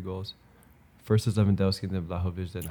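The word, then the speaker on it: Vlahović: Why'd you say his name like that? – Now you say his name? – Kind of disrespectful. Vlahovic. – Isn't it Vlahovic? – Disrespectful. Right. Yeah, Vlahovic. I didn't know that.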